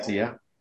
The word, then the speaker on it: Sia: You know, Sia, is how you pronounce her name? – Yeah. – Sia.